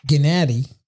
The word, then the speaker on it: Gennady: Gennady, that's how you're supposed to say his name, Gennady Golovkin.